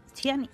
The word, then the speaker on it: Ciani: que es la de Susan Ciani. ¿Cómo se dice? – Susan Ciani. Tiene origen italiano.